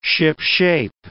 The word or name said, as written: shipshape